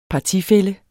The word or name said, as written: partifælle